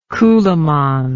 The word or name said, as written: coolamon